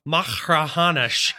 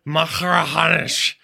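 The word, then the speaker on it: Machrihanish: I got schooled pretty good the other day talking to David McClay Kidd about Macrahanish, and he made me pronounce it over and over and over again to really get the back of the throat Macrahanish going.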